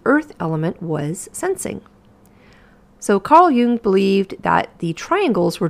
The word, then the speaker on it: Jung: So spring forward to the 20th century, and it was Carl, I know you don't pronounce his name Jung, and it was Carl Jung in 1921 who took the four humors of Hippocrates and broke them down into personality types.